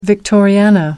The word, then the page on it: victoriana: American English